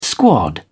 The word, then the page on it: squad: British English